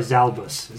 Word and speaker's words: Zalbus: Next we have our big bad, which we already know is Lord Zalgus. – Lord Zalgus. – The Space Lord... Oh, I'm sorry, Zalbus. Z-A-L-B-U-S.